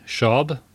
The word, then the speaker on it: Schaub: Schaub, David P. S-C-H-A-U-B.